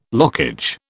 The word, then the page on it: lockage: British English